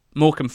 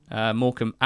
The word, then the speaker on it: Morecambe: Tom, who is our Morecambe fan from Peru on the marvellous NTT20 squad, called this the Silent B Derby. Both Wickham and Morecambe having a rogue B in there.